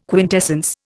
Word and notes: quintessence: Indian

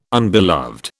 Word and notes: unbeloved: British English

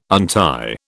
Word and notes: untie: British English

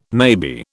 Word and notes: maybe: British English

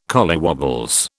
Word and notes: collywobbles: British English

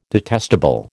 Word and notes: detestable: American English

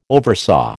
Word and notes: oversaw: American English

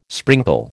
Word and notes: sprinkle: American English

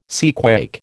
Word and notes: seaquake: American English

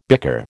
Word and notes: bicker: American English

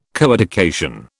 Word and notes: coeducation: British English

